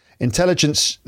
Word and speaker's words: intelligence: Uh, arti- artificial intelligence, uh, intelligence, the stress is on the second syllable, but it's put it on the first syllable, um, allows users to interact with a virtual assistant.